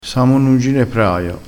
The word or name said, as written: ginepraio